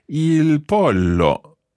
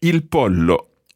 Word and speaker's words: pollo: Just watch your pronunciation because, of course, in Spanish it's pronounced slightly differently, but it's written exactly the same. Il pollo.